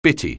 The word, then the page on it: bitty: anglais